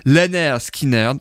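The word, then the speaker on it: Lynyrd Skynyrd: qui s'appelait Leonard Skinner, prononcé Lenner Skinner, voilà, tout simplement. Euh, c'est tout simplement comme ça, ils ont juste modifié l'orthographe malgré tout, mais sinon la prononciation est la même et c'est comme ça que le nom du groupe leur est venu.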